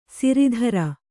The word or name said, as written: ಸಿರಿದರ